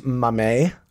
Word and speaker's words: Mamet: I believe it's pronounced Mamet. – Oh, is it Mamet? – Yeah.